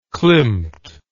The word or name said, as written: klimt